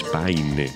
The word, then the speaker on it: Paine: Sin sufrimiento no hay ganancia, pero bueno, jugando con Pain, que sería pena, con su apellido que es Paine, Pain también se pronuncia.